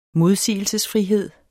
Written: modsigelsesfrihed

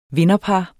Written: vinderpar

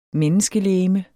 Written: menneskelegeme

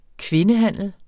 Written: kvindehandel

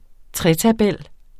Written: tretabel

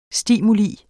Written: STIMULIENE

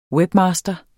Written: webmaster

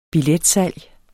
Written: billetsalg

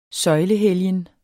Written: søjlehelgen

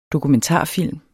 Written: DOKUMENTARFILMEN

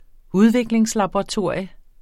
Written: udviklingslaboratorium

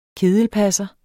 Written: kedelpasser

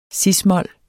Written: cis-mol

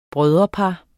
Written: broderpar

